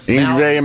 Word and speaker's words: Yngwie: How do you say this guy's? Yevgeny Malmsteen? – Yngwie Malmsteen.